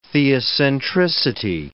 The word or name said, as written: theocentricity